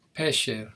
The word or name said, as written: pesher